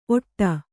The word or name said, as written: ಒಟ್ಟ